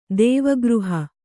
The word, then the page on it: ದೇವಗೃಹ: dēva gřha